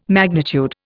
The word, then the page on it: magnitude: Indian